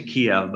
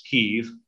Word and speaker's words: Kyiv: Uh, the Church of Kiev now, Westerners often say Kiev, but the proper pronunciation is Kyiv, uh, is really the mother church.